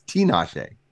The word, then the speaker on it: Tinashe: It's Tinashe.